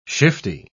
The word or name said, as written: shifty